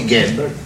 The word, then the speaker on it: Gable: Il y a écrit Gable. On doit prononcer Gable. Ah.